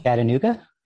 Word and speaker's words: Chattanooga: And for those who are attending the, I don't know if I'm going to say it, I always say it wrong, Chitunga Film Festival? What's the other one you're going to? I always say it wrong. – Chattanooga? – Yeah, that's Chattanooga.